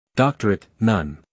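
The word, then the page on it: doctorate: uk ˈdɒktərət; en-us ˈdɑːktərət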